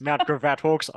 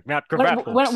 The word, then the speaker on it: Mount Gravatt: Shout out to her grassroots club, Mount Gravatt Hawks. – Gravatt. 100. Sorry. – What? – What? Gravatt. Sorry, I can't let that pass. – Yeah, this is Mount Gravatt Hawks.